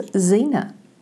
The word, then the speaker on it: Xena: But hello, my name is Xena. That is spelled with an X.